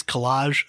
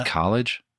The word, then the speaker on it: collage: Uh, on the inside cover, uh, there's a nice collage of a close up made of pictures of uh, Fantastic Negrito's face. That's pronounced uh, college.